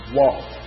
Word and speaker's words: waw: And if we look in the Hebrew word for this "and" is "waw." All right? "Waw." It's like W-A-W. There's different variations of spelling, but as far as pronunciation goes, "waw."